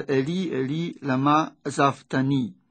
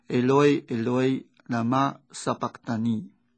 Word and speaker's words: Eloi Eloi Lama Sabachthani: 原来在圣咏里面呢，第 22 首第二节是希伯来文，希伯来文的念法就是 Eli Eli Lama Sabachthani，这样的一句话到了耶稣口中，当时啊用当时通用的阿拉美文就念成 Eloi Eloi Lama Sabachthani。